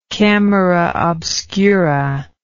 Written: camera obscura

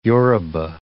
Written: Yoruba

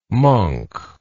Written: monck

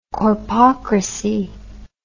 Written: corpocracy

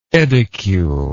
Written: edicule

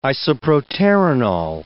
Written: isoproterenol

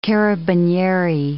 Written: carabinieri

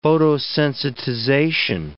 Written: photosensitization

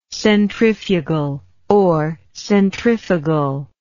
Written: centrifugality

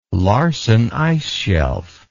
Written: Larsen Ice Shelf

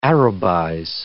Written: Arabize

